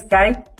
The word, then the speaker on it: Kite: No tiene ningún significado su nombre, simplemente así se pronuncia, así es, Kite.